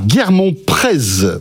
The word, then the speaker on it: Guermont-Prez: Gaspard Guermont-Prez. On prononce pas le Z.